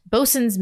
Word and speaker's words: boatswain: Boatswain's mate says it's pronounced bosun's mate.